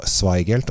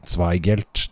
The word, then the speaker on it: Zweigelt: og da uttalte jeg et Zweigelt Altså, jeg uttalte det feil. Jeg sa Zweigelt, og så er det Zwei. Altså ein Zwei, Zweigelt. Det er veldig viktig å si det.